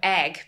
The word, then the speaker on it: Eigg: And then when I was 6, we moved off to the Isle of Egg, pronounced like egg. – An egg. Yeah, E-I-G-G, like an egg.